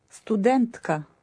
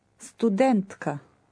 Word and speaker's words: студентка: Студентка. Студентка.